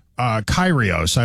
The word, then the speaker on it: Kyrgios: Kyrgios. I looked it up. I checked out the pronunciation. It's got a G in it, but it's Greek, so I guess it's...